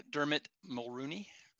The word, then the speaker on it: Dermot Mulroney: Is that how you say his name? Robert Lagosha. – Dermot Mulroney. – Yes. Yeah. Yeah.